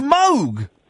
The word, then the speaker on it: Moog: It's like the Moog synthesizer is actually pronounced Moog.